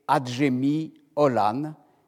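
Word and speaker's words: Acemi oğlan: Alors retenez bien ces mots, Adjemi Olan, que j'ai transcrits comme on les prononce en français. Le mot n'est pas écrit de la même façon en turc. On écrira A-C-E-M-I et puis plus loin Olan.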